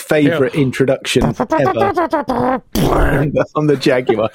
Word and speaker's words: Jaguar: Jaguar. Make it three syllables.